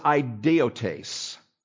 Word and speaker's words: idiotes: The word for rude in the Greek here in chapter 11 and verse 6 is idiotes. It is spelled I-D-I-O-T-E-S.